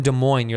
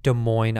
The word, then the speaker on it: Des Moines: Des Moines, Iowa. And it's Des Moines, not Des Moines. The silent S's in the Midwest.